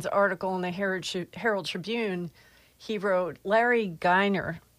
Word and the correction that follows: Geimer: am I saying that right? Geiner? – Geimer. – Oh, okay. Geimer.